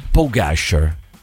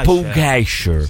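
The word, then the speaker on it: Pogačar: Comunque, visto che ci tiene la pronuncia esatta dei nomi, Maurizio, si pronuncia Pogacar. No, Pogacar. – Pogacar. – Pugacher. Si pronuncia, Maurizio, Pugacher.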